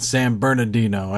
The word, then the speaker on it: San Bernardino: There's a little apostrophe there between those. So it's not San Bernardino. I didn't say it wrong. It's San Bernardino.